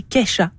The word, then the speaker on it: Kesha: Oui, et on dit Kesha.